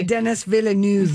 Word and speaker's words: Denis Villeneuve: Uh, Denis Villeneuve.